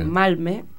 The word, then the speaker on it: Malmö: Puntualizan desde una ciudad que se llama Malmö, y dicen que se pronuncia así.